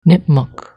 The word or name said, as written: Nipmuc